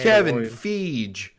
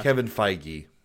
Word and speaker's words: Kevin Feige: Kevin Feige. – Yeah. – Kevin Feige.